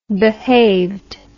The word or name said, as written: behaved